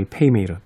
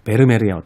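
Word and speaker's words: Vermeer: 그렇게 발음을 해야 된다는 라 이야기를 또 하시더라고요. 그러면서 아마도 고호 어, 이후에 가장 전 세계인들의 관심을 모으고 있는 어, 작품 중에 하나가 바로 이 페이메이르, 베르메르의 어떤 그림일 것이다.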